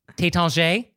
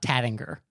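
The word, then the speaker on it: Taittinger: But then as long as we're talking about champagne names, Tétanger, it's not Tattinger.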